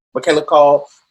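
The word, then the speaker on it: Michaela Coel: And recently in I May Destroy You, Michaela Cole, is that how you say her name? – Mm-hmm. – Michaela Cole.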